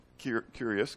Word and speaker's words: Kyrios: It looks like Kyrios, Kyrios, okay? It's pronounced Kyrios, okay?